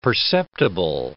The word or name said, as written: perceptible